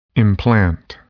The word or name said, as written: implant